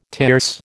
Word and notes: tierce: American English

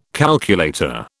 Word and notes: calculator: British English

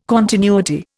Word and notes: continuity: Indian

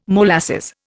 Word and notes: molasses: Indian

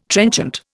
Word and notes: trenchant: Indian

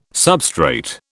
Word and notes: substrate: British English

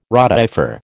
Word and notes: rotifer: American English

